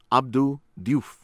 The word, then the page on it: Abdou Diouf: American English; from Senegal